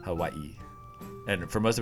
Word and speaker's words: Hawaii: Like we kind of say it as though it's going to be two syllables. But then we realize like, oh no, there's three syllables in that. We're like Hawaii.